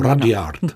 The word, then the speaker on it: Rudyard: Ano, Rudyard.